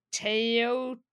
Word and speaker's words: Teotihuacan: the pronunciation guide says Teotihuacan.